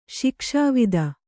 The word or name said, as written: ಶಿಕ್ಷಾವಿದ